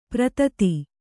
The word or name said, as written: ಪ್ರಥಿತ